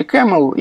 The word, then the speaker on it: Caml: Произносится по-английски CAML, и, собственно, все эти аллюзии на верблюдов.